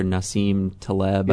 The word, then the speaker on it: Nassim Taleb: In that, I can't remember which book it was, but that, you know, that author Nassim Taleb, yep, I think is, yep, how it's pronounced.